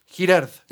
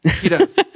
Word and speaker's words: Girard: Es la, la, teoría de René eh, Girard, espero estar pronunciándolo bien. Es la, Girard, Girard.